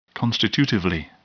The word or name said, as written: constitutively